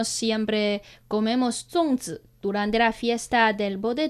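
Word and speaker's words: Zongzi (粽子): Zongzi. Zongzi.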